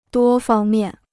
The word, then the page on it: 多方面: duō fāng miàn